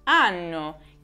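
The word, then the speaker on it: hanno: In particolare, questa differenza avviene quando c'è di mezzo la lettera H, che in italiano si scrive ma non si legge. È il caso, per esempio, delle parole come anno, che è un sostantivo, per esempio 2020, e anno, che è invece voce del verbo avere.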